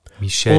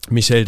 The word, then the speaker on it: Michel: Michel. Und ich glaube, sein Vorname wird französisch ausgesprochen. Ist. – Michel, das wird wahrscheinlich stimmen, weil er ist auch aus Paris weggezogen.